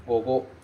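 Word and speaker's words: gogo (午後): Ele se pronuncia gogo, gogo.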